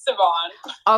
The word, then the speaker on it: Saban: I didn't know if people pronounce it Saban or Sabretooth. – I'm pretty sure it's Saban.